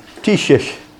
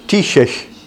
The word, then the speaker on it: Taoiseach: Taoiseach. Taoiseach.